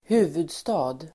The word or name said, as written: huvudstad